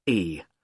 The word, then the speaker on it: Carlisle: It's Carlisle. You don't pronounce the S.